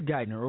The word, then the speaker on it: Geithner: Peter Geithner oversaw the microfinance programs developed in Indonesia by Ann Durham Sotoro, Barack Obama's mama. Barack Obama appointed Peter Geithner's son, Timothy Geithner, as Secretary of the Treasury.